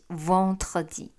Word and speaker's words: vendredi: 者来说，mercredi 星期三跟星期五 vendredi 可能会比较难一点点，因为星期三有可跟和的音要放在一起，所以就念成 mercredi。那星期五有的跟和也是要连放在一起 vendredi。